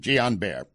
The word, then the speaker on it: Guillain-Barré: Or Guillain-Barré, as if some, that might be a closer pronunciation, Guillain-Barré.